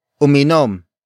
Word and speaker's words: uminom: The first word is uminom. Uminom. Uminom. Uminom is spelled as U-M-I-N-O-M.